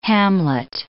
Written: hamlet

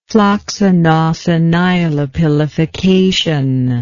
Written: floccinaucinihilipilification

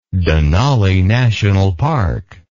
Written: Denali National Park

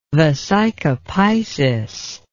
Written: vesica piscis

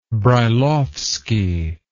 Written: brailowsky

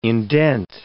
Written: indent

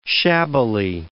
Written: shabbily

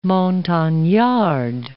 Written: montagnard

